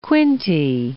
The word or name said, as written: Quinte, Bay of